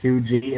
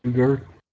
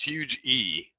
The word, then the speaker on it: Huger: Wait, how they say Hugie and not Huger? – Then there's no R on it. It's huge E. For the locals, there's no R. Even though it's spelt with an R, they don't pronounce the R.